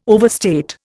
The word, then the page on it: overstate: Indian